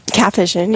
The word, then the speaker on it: Capuchin: Capuchin, yes.